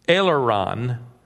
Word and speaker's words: aileron: During a slow roll, as he made his approach, he suddenly felt his aileron lock. Do we have any pilots in here? Is that how you pronounce it? Aileron? Thank you.